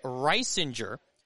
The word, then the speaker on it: Reisinger: But Reisinger, R-E-I, and then Singer, S-I-N-G-E-R, but pronounced Reisinger, and he will take over on the bump.